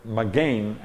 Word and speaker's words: magen: Now the magen actually is how it's properly pronounced.